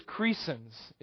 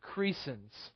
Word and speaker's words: Crescens: I believe it is Crescens is the right way to say his name. Crescens.